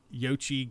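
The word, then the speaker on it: Xochitl: It's like Yochi Gomez is how you say her name.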